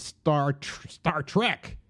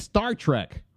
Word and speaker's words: Star Trek: They were very permissive with us, but with each other, if they put the accent on the wrong syllable. Mm-hmm. It's like, no, you said Star Trek. Right. It's Star Trek.